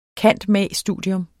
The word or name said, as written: cand.mag.